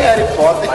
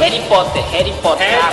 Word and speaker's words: Harry Potter: Mas calcule, os fãs de Harry Potter, crianças... – Harry Potter. – A minha pronúncia tá uma merda. – Harry Potter, vai.